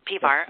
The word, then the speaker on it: Pivar: This the soundtrack was written and performed by world-class musician Jeff, is it Tavar? Did I say that right? Pivar, okay, who is known for his work with David Crosby, Ray Charles, and Ricky Lee Jones, and others.